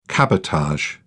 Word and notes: cabotage: British English

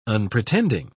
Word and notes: unpretending: British English

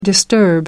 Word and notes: disturb: American English

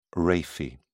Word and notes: raphe: British English

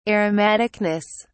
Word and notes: aromaticness: British English

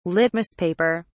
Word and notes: litmus paper: British English

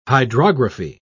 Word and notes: hydrography: British English

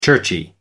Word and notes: churchy: American English

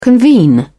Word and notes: convene: British English

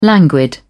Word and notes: languid: British English

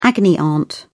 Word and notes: agony aunt: British English

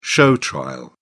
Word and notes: show trial: British English

